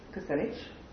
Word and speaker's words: Kucinich: He has served as the press secretary for Dennis Kucinich. Did I pronounce his name right? – Yep.